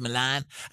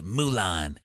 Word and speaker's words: Milan: Calvin Klein flew me out to Milan. I didn't even know how to pronounce it. I called it Mulan.